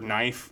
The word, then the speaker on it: knife: So when you read the letter, you'll see that the word knife, they include the silent K, and also the H in the word while.